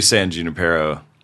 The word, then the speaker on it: San Junipero: I want to pronounce it in like a more... – I think it's probably Spanish. You probably can't. – Yeah, but it's a made-up place. – Yeah, they say San Junipero.